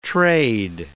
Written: trade

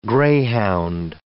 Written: greyhound

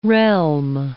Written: realm

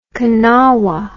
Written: kanawa

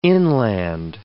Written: inland

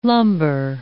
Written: lumber

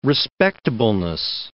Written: respectableness

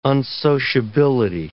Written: unsociability

